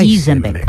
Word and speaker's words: Isenbeck: ¿No se dice Isenbeck? No, es alemán. Isenbeck. Ah.